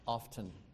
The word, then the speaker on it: often: The word O-F-T-E-N is not pronounced often. That's not the, according to this book, that's not the correct pronunciation. Of course, the book was a Bob Jones University textbook, so maybe that has something to do with it. Well, James isn't here to tease him. But according to the textbook, the correct way to say that is often, like O-F-F-E-N.